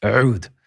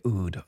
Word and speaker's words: Oud: It's pronounced in, in it's pronounced Oud, but we say Oud, O U D.